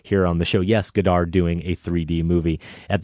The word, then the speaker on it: Godard: Godard and 3D.